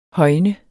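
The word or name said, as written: HØJNET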